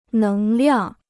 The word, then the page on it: 能量: néng liàng